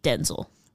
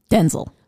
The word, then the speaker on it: Denzel: Has revealed that his real name, the way that you pronounce it, is actually Denzel. – Denzel.